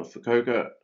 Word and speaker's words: Fukuoka: Uh, Fukuoka. I, I think I said it right the first time. You did, yes.